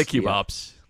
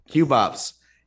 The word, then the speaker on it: QB ops: How do we pronounce it? We say cube ops. – Cube ops.